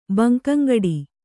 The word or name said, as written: ಬಂಕಂಗಡಿ